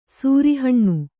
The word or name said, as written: ಸೂರಿಹಣ್ಣು